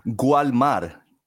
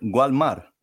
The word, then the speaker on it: Walmart: It's spelled Gualmar, Gualmar, Gualmar. Okay, that's how we say it. That's our phonetic spelling for it, Gualmar.